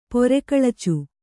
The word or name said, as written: ಪರೆಗಳಚು